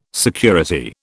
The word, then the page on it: security: British English